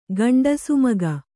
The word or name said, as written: ಗಂಡಸುಮಗ